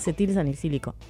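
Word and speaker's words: acetilsalicílico: En el año 1839, está, en el año 1897, Felix Hoffmann descubre el ácido acelitásico, conocido como la aspirina, y es un fármaco de la familia de los salicilatos. – Se utiliza... Es acetil salicílico. – Sí, lo dije mal, ¿vio?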